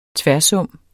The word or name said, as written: tværsum